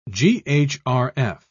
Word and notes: ghrf: British English

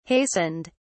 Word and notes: hastened: British English

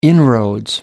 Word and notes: inroads: British English

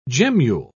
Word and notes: gemmule: British English

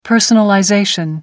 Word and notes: personalization: American English

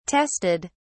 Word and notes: tested: British English

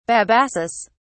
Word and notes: babassus: British English

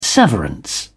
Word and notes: severance: British English

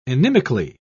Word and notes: inimically: British English

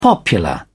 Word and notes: popular: British English

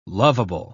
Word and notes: loveable: British English

